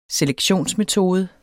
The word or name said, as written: selektionsmetode